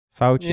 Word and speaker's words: Fauci: How do you say his name? Fauci? – I think. – Face. – Fauci, yeah. That too.